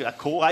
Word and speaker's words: Accor: I think I pronounced that correctly. Accor, Accor Stadium.